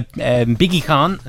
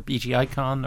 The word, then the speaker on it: BGICon: So yeah, BiggieCon, or BGICon, or BigeCon, or however you want to pronounce it. But please pronounce it BiggieCon, that's my brand. My branding is very important to me.